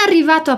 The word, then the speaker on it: arrivato: Aqui a gente se concentra na pronúncia do R, em arrivato. A gente tem uma pronúncia bem forte, a pronúncia dos dois R's, que pode ser meio desafiadora porque poucas pessoas a utilizam em português.